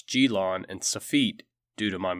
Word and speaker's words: Gelon: First, I have been calling the first Greek tyrant of Syracuse Gelon, when in fact I believe the pronunciation is Gelon.